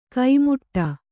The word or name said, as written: ಕೈಮುಟ್ಟ